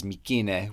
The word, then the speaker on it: Mycenae: I wrote the script with C's, but I pronounced everything as K's, and I pronounced Mycenae as Mykine, which is one of the modern Greek ways to pronounce it.